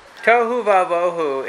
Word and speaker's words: tohu vavohu: So, tohu vavohu, it's a poetry. It rhymes in Hebrew.